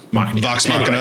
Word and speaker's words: Vox Machina: Am I saying that right? Machina? – Okay. Vox Machina.